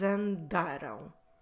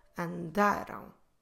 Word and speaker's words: andaram: Stress the second to last syllable. Andaram. Andaram. But when you finish, in the end, close your lips in order to make the nasal sound ão. But don't stress it.